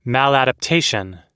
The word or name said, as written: maladaptation